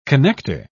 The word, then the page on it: connecter: British English